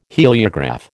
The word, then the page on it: heliograph: American English